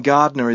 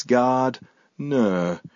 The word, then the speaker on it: Gardner: And Gardner is not Gardener or Gardiner. It's Gardner. There is no extra I or E because that's how you say it in Australian. Gardner.